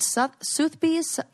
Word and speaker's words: Sotheby's: how do you say the name of this auction house? Is it Sotheby's? Uh-huh.